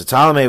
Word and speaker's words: Ptolemy: All right, so Ptolemy was a mathematician, and he kind of figured out.